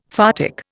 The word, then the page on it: photic: Indian